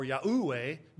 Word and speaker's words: Yahweh: Well, the fact is, Yahweh's name is expressed by the Tetragrammaton, four letters, YHWH, and that by necessity is two syllables, Yahweh. Two syllables, YH for Yah, WH for Weh or Wah, as some prefer, and probably a little more accurate.